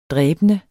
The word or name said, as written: dræbende